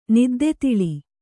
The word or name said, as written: ನಿದ್ದೆತಿಳಿ